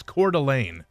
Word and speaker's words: Coeur d'Alene: Yes, I believe texters pronouncing the it's Coeur d'Alene, Idaho.